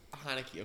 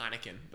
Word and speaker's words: Heinicke: Heinicke, okay. I was going to say, how do you pronounce it? I was going to say Heineken, but that's not the...